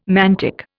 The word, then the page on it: mantic: Indian